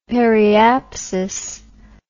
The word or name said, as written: periapsis